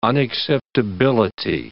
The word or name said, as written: unacceptability